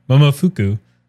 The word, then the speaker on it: Momofuku: Momofuku.